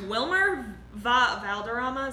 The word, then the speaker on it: Wilmer Valderrama: Wilmer Valderrama, is that how you pronounce it? – Yes.